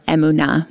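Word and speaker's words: emunah (אמונה): Emunah, or emunah, more properly pronounced in Hebrew.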